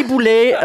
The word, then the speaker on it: Boulet: Mais Julie Boulet, on le sait, on le prononce Boulet, mais Guy Bertrand, le premier conseiller linguistique de Radio-Canada, vient d'émettre une suggestion de que c'est désormais plus avisé de prononcer boulet.